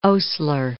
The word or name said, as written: Osler